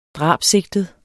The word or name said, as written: drabssigtet